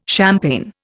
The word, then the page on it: champagne: Indian